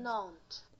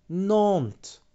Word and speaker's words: Nantes: Nantes. – Nantes. Nantes. Of course, yeah, you don't pronounce the last letter in French, do you?